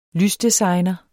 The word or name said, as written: lysdesigner